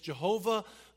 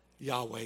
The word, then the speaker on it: Yahweh: And they took the name of God, Adonai, another name of God, and put the vowels between those letters, which is transliterated into Jehovah. But scholars today believe a better pronunciation is what? Yahweh, exactly. You should know that. The all-powerful personal name of God, Yahweh.